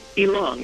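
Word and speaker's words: Ylang: Ilang. It's one half of the word for the fragrance, Ilang Ilang.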